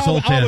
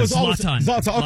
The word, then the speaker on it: Zlatan: Yeah, I used to call him Zoltan. – Zlatan. Okay. Zlatan. Even the beef between, you can tell those two guys do not like each other.